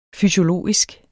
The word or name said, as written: fysiologisk